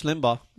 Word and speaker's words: Limbaugh: Republican radio commentator Rush Limbaugh. Am I saying it right? – Rush Limbaugh.